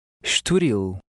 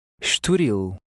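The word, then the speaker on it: Estoril: The I-L is pronounced like the English word eel. In words ending in L, the stress is on the last syllable. Try it. – Esturil. Esturil.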